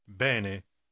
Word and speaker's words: bene: Обратите внимание на произношение буквы «э». Повторите слово 15. – Bene.